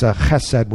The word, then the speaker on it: Chesed: Verse 10, the son of Hesed, or Ben Hesed. Hesed, I'm not good at pronouncing it. It's Chesed would be mercy.